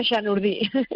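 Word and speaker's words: Chanourdie: Oye, hemos empezado hablando de esta escaladora francesa, de Julia Chardonnay, no sé si lo digo bien o no, pero que es una... ¿Cómo se dice? – Julia Chanurdi. – Chanurdi, bueno, pues esta mujer que es un animal,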